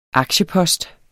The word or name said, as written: aktiepost